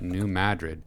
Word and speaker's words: New Madrid: Now, listeners and viewers, Kyle and producer Cameron scoffed at me when I pronounced that New Madrid, even though I'm 99% sure that's how the locals would pronounce it.